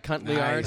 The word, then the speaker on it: Cotillard: Her name is Cuntley? – Cotillard. Nice.